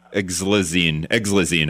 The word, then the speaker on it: xylazine: Yeah, it looks like exlizine. How's that? – Yep. – X Y L A Z I N E.